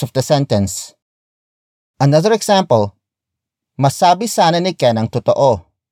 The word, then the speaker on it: masabi: The first word is masabi, masabi, masabi. Masabi is spelled as M A S A B I.